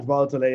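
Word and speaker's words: Bahutule: CricBuzz understands that India's Siraj Bahutale, did I pronounce that right? – Siraj Bahutale, yeah.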